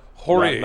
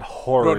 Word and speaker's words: Hori: And that's Hori spelled H-O-R-I. – Yeah. – Hori. – Not Hori. – No, more like Hori.